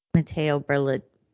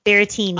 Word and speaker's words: Berrettini: I promise I won't unless I get propositioned by Matteo Berlini. I always get his name right. Bertolini. – So you promise, but like, you have hall passes. Like, there's some exceptions. That's understandable. – Berrettini.